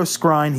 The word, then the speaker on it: Skrein: We had a debate off camera of how to pronounce this. We're going to go Skrein. He steps down from the Hellboy reboot film over concerns of potential whitewashing.